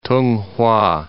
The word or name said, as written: Tonghua